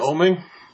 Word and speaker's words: Avon Oeming: How do you pronounce it? Avon Oeming? – That's it.